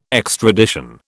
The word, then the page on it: extradition: British English